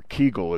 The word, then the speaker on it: kugel: But because Kugel, or Kegel as some people pronounce it, or Pashtida as we would say in Hebrew, which doesn't much sound like a Hebrew word, but that's what it's called.